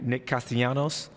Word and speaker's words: Castellanos: But that's legit, because Nick, I hope I say his name right, Nick Castellanos. – Yeah, yeah.